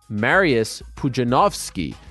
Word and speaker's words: Pudzianowski: I've I've taught myself that we've been saying it wrong for the past uh, two decades. Mariusz Pudzianowski. The W is a V in Polish.